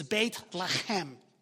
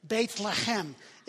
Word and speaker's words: Beit Lechem: You know, the proper pronunciation of Bethlehem in Hebrew is Beit Lechem. Try that at home and say that with people. You have to get the sign out right. Beit Lechem.